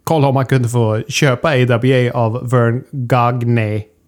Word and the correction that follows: Gagne: Hur fan uttalar man det? Inte så. Ganja. Ja, Vern Ganja.